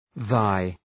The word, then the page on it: thy: ðaı